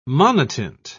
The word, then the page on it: monotint: British English